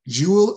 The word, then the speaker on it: Jewil: That's spelled J-E-W-I-L, Jewel International.